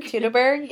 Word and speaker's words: Teutoburg: Teutoburg? – Teutoburg, yes.